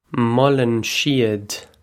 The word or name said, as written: molann siad